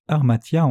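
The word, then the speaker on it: armatia (ἁμαρτία): Le mot péché se prononce armatia en grec, ce qui signifie manquer le but.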